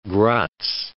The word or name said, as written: Graz